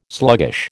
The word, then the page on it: sluggish: American English